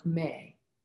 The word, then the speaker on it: MEH: We have many, many Rosicrucian techniques that can help us with that, especially the vowel sound May, spelled M-E-H and pronounced like the month of May.